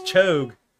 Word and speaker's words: Cho'Gath: It's one word. It's Cho'Gath. The Cho'Gath. Ath is the second half of that syllable. It's not Gath. It's Ath. Cho'Gath.